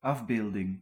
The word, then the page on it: figure: [fi.ɡyʁ]; France